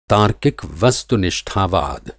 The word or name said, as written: तार्किक वस्तुनिष्ठावाद